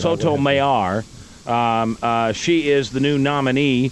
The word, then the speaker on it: Sotomayor: And the guy on NBC pronounced it different. – Mayor, he said Mayor. – Mayor? – Yeah, he said Sotomayor. – That's probably more accurate, I would imagine.